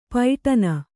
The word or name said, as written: ಪೈಟನ